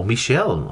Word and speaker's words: Michel: Michael Sarjawi, that's the M-I-C, or Michel, actually, sorry, call me Michel Sarjawi. S-A-J-R-A-W-Y, and Michel Sarjawi.